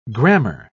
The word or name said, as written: grammar